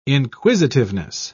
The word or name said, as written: inquisitiveness